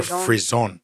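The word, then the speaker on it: frisson: Frisian is a French word pronounced Frisian or Frison, right? – Oh.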